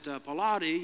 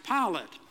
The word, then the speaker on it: Pilate: Of course, I could have said to them, you know, I'm kind of a religious scholar, and I know you don't pronounce it Pilate, you pronounce it Pilate.